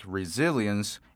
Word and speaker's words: resilience: Our second tip focuses on another word in the sentence, which is resilience. This word has five syllables, and the stress should be on the second Z sound. Although it is spelled as S-I, it should be pronounced as Z-I, Z, instead. Also notice that the second half, L-I-E-N-C-E, should be pronounced articulately, syllable by syllable, as li-ns, not li-ns.